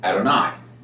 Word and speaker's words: Adonai: And in Hebrew, when reading through, it will be pronounced Adonai.